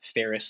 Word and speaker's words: Faris: Faris, and that's Faris spelled like Paris, but with an F, not like Ferris Bueller.